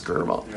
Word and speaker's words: Skirball: Skirball? Two syllables, Skirball. – Yeah, Skirball.